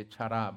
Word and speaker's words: chara: We're having the discussion, Jessica and I, because it's spelled C-H-A-R-A. But it's not, with my Spanish roots, I would say chara, but it's not. It's chara.